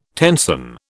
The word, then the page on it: tenson: British English